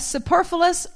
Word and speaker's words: superfluous: Leviticus says there was a group that was, and if I'm saying this wrong, please correct me, a superfluous or superfluous. How do we say that word? Superfluous. Okay, that's how I think I said it last.